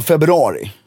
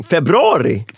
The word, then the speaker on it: februari: I mitten av februari. – Mm. Februari? – Februari. Mm. Februari heter så. – Det är, jag kommenterar det varje gång. – Ja, vad säger jag? – Du uttalar inte U-et.